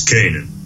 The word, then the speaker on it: Canaan: So I picked the story of Joseph in the Old Testament, and he pretty much lived in Israel, and I guess they pronounce it Canaan or Canaan. And Joseph, he was the son of Jacob. – It's Canaan. – Canaan? – Okay. Yes.